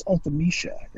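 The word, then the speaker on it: Ultimecia: But what I found really interesting about the song Premonition is that in the final boss battle against Ultimisha, yes, Ultimisha is how it's pronounced. Thank you, Dissidia.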